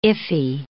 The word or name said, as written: iffy